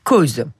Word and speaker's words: Coase: C'est l'économie du droit et il s'appelle Ronald Coase. Alors, je ne sais pas si je le prononce correctement. – Coase. – Ça s'écrit Coase. – Voilà, c'est ça.